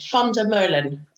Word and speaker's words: Van der Meulen: You pronounce it Van der Meulen. It basically means Miller.